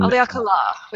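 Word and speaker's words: Haleakalā: And Haleakala, with a little emphasis in the last syllable.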